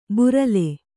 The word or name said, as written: ಬರ್ಲು